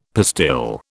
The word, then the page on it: pastille: British English